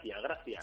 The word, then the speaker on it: gracia: Que pronuncias mal, quería decir gracia.